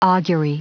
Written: augury

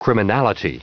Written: criminality

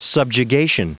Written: subjugation

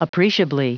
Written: appreciably

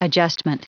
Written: adjustment

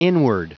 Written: inward

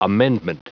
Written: amendment

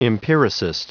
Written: empiricist

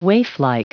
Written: waiflike

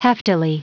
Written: heftily

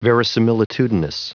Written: verisimilitudinous

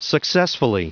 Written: successfully

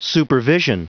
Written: supervision

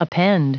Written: append

